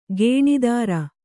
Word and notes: ಗೇಣಿದಾರ: Kannada

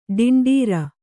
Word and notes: ಟೆಂಡರು: ṭenḍaru

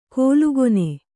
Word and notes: ಕೋಲುಗೊನೆ: kōlu gone

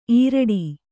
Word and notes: ಈರಡಿ: īraḍi

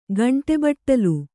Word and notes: ಗಂಟೆಬಟ್ಟಲು: gaṇṭe baṭṭalu

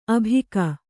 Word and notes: ಆಭೋಗ: ābhōga

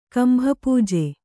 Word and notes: ಕಂಭಪೂಜೆ: kambhapūje

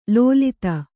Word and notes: ಲೋಲಿತ: lōlita

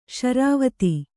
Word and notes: ಸಿರಿವೊತ್ತು: Kannada

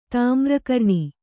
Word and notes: ತಾಮ್ರಕರ್ಣಿ: Kannada

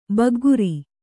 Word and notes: ಬಗ್ಗರೆ: Kannada

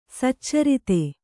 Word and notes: ಸಚ್ಚರಿತೆ: saccarite

